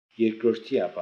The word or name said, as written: Erkrordiapan